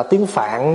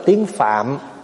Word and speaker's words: phạm: Mà mình bây giờ mình nói gọn lại là tiếng phạn đó. Mình nói tiếng phạn đó, chứ nếu mà nói phát âm cho đúng phải nói là tiếng phạm.